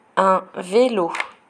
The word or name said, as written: un vélo